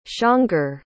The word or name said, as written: csongor